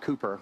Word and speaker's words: Cowper: It's actually spelt C-O-W-P-E-R, but it's pronounced Cooper, because that's the way English do things.